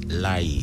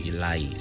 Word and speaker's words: Lary: The name is Lai. – Oh, how do you pronounce it? Lai. – Lai. – So, oh, okay. – L-A-R-Y. Lai. – L-A-R-Y.